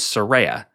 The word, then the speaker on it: Saraya: However, I would not say this match was pointless because it taught me forever that the correct way to say her name is Saraya.